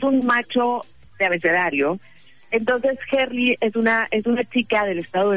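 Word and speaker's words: Herli: Gerli con H, H-E-R-L-I, Gerli, y tiene dos letras después de Gerli.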